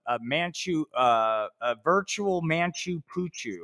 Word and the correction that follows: Machu Picchu: I, I always mispronounce that mispronounce that hold on um Machu Picchu? – Yeah, virtual.